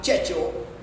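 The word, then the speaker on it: Cecco: that is a man called Checco, right? That's if you want to say the C-E-C-O, right? Or you have Checho, right? So these are two ways it's pronounced, right?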